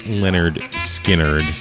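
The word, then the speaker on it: Lynyrd Skynyrd: Leonard Skinnerd.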